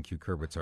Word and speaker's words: cucurbits: Tiffany has posted this question on Facebook, and the word she's used is spelled C-U-C-U-R-B-I-T-S.